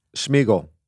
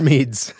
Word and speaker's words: Smedes: I don't know how to say his last name. Smedes? – Smeagol. – Okay. No, it's Smeeds.